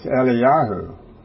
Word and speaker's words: Eliyahu: And so when you pronounce Elijah's name in the Hebrew, it's Eliyahu.